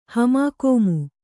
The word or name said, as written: ಹಮಾಕೋಮು